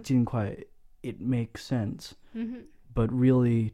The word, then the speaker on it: 尽快: Yeah, they're saying 尽快, fourth tone.